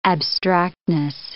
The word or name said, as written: abstractness